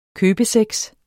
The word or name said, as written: købesex